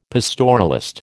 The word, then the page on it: pastoralist: American English